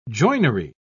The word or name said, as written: joinery